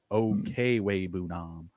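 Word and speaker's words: Okwuegbunam: Dude, any football fans out there that play fantasy hockey, like Albert Okwubanom is nothing to this guy's name. Like, and even I think I just said that wrong. It's Okwubanom.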